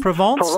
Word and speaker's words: Provence: Are we talking about Provence? Did I pronounce that correctly? – Is it Provence? – Provence.